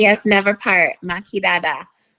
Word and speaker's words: Maki Dada: Oh, Maki Dada. – Maki Dada.